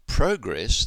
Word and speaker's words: progress: But progress, the noun, has the stress on the first syllable.